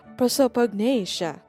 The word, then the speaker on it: prosopagnosia: They spiced it up, kumbaga, by having the main lead suffers prosopagnosia. Hul ang pag-pronounce. Prosopagnesia.